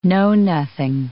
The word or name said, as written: know-nothing